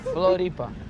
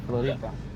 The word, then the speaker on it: Floripa: Floripa. – That's it. End of it then. We in Floripa. – We're in Floripa. – No, Floripa. – We're in Floripa. – Floripa. – There you go, Floripa.